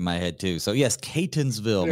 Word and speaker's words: Catonsville: Catonsville. There, you want to put an N at the beginning. We all do. But there's no N at the very beginning.